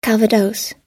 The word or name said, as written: calvados